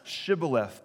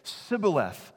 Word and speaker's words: Shibboleth: Shibboleth. But when an Ephraimite tried to say it, they couldn't make the SH sound, and they would just say Sibboleth. They were easily identified because they couldn't pronounce the word properly.